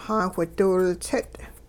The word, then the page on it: xaawhidiwiltsit': Hupa